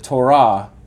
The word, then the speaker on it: Torah: And it does have religious people in it, but it's not It's not like run by the principles of the Torah like ancient Israel was.